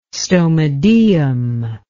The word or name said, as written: stomodeum